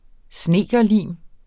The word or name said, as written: snedkerlim